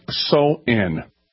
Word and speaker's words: Samhain: Halloween's origins date back to the ancient Celtic festival of Samhain, pronounced so-in.